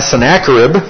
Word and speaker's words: Sennacherib: Now, in the 14th year of King Hezekiah, Sennacherib, that's how you pronounce that name, king of Assyria, came up against all the fortified cities of Judah and seized them.